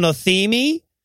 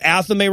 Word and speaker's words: athame: and he calls it an athemi. and I'm like, you know, everybody mispronounces that. I don't expect him to get athame right, but like that is the dumbest sounding possible mispronunciation, isn't it?